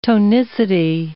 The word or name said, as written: tonicity